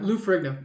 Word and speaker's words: Lou Ferrigno: Lou Ferrigno.